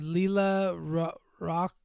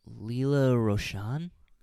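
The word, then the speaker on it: Lela Rochon: You had Leela Rock. How do you say that? Rochon. – Oh, fuck! I gotta get up to read this name. Leela Rochon.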